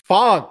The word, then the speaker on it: phonk: Funk, like P-H-O-N-K. Am I saying it wrong? – It's funk, YC.